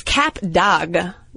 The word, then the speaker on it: Cap d'Agde: And it's Cap d'Agde.